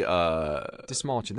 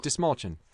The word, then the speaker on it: Dastmalchian: Dastmalchian. That's three syllables. Dastmalchian.